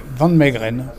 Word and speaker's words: Van Meegeren: Van Meegeren, assez simple finalement. Un nom hollandais, puisque le personnage est né aux Pays-Bas.